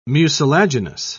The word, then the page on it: mucilaginous: British English